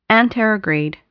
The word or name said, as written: anterograde